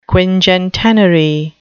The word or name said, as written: quingentenary